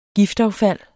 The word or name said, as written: giftaffald